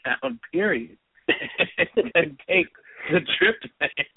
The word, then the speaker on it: tryptophan: The one that I have tried to explain is the tryptophan. And I say, even saying it right, tryptophan or tryptophan? – Tryptophan, yeah.